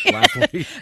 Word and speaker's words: Schlafly: Is it Schlafly or Schlafly? Schlafly. – I don't know. She might not deserve me pronouncing her name correctly. – Phyllis Schlafly.